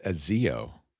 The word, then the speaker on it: ASIO: But I think Darren's saying it's a long A. ASIO.